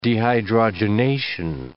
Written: dehydrogenation

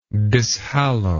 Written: dishallow

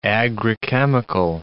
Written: agrichemical

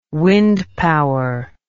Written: wind power

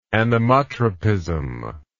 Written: anemotropic

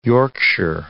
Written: Yorkshire

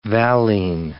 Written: valine

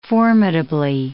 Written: formidably

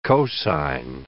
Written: cosine

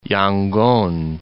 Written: Yangon

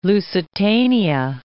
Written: Lusitania

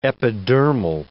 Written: epidermal

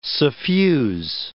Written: suffuse

